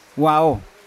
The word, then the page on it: wao: Yawa